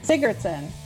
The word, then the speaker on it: Sigurdsson: The last name is S-I-G-U-R. Keep going. D-S-S-O-N. And how do you say that? – Sigurdsson.